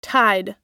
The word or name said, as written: tide